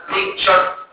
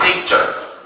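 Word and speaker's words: tincture: مش حاجه اسمها بيكتشر ايبي ايه معكحول، يبقى اسمها ايه تينكتشر.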